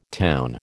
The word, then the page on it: town: American English